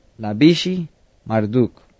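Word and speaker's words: Labashi-Marduk: Y le entregó el imperio a su hijo, quien era, en el inglés se pronuncia Labishi Marduk.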